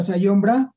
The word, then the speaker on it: Zajombra: Las redes sociales es Zajombra, Zajombra, se pronuncia Zajombra, pero búsquenlos, escuchen sus rolas, son vivencias.